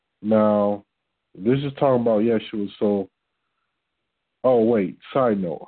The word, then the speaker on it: Yeshua: So when I'm saying yes, yes, Y-E-S, Yeshua, I consciously know what I'm doing because I was taught this. Because that would be the more proper way to pronounce it, particularly for an English translation.